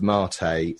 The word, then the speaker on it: mate: And I'm told it's pronounced Mate like latte,